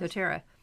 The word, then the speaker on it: doTERRA: And then your specific company, doTERRA, did I say that right? – Yes. – doTERRA.